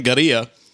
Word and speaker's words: Gaerea: so the band Gaerea, is uh, spelled G A E R E A. I can't believe I didn't butcher that, um, as I always do.